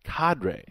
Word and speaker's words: cadre: He is one of a cadre. Is that a good way? – Oh, nice.